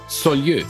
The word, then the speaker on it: Saulieu: Ab 88 Hotel de la Poste in Saulieu. – So spricht man das nämlich aus.